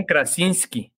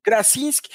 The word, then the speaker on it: Krasinski: John Krasinski. Krasinski.